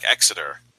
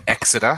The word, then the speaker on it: Exeter: So you're going to be the, you're going to be in the Exeter. How do I spell that? – E X E T E R. – E oh, like exit. E X. But with all E's, yeah.